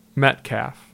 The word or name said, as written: Metcalf